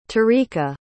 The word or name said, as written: tarika